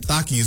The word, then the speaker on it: Takis: The fact that Takis, one of my favorite chips, now has two new cancer-causing contaminants.